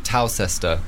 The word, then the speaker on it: Towcester: No, it's spelled like Towcester. – Towcester, like Worcester. – Yeah, Worcester, Leicester, Gloucester.